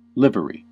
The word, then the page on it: livery: American English